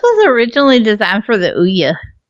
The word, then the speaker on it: Ouya: I also had to do a YouTube search on Ouya so I could pronounce it right.